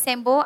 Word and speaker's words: Sembo: Nama perfume Farah, perfume saya ejaan dia S-E-M-B-O. Mm-hmm. Ada yang panggil Sembo, ada yang panggil Sembo.